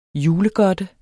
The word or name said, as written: julegodte